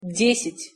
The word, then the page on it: desjat-: Russian